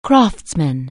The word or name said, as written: craftsman